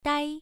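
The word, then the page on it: dai: tone 1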